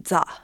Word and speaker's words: ザ: 我们今天就吃几个它的旗下最有名的一个 ZA 系列，就是 ZA 就是一个英语的 the T H E，它在日语里面的发音就是 ZA，然后它就出现了很多 ZA 炸鸡块、 ZA 炒饭。